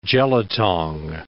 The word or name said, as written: jelutong